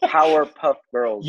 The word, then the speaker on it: Powerpuff Girls: You said Powderpuff. – Yeah. I knew it. When I said it too, I was like, okay, like I think I said this wrong. But Powerpuff Girls.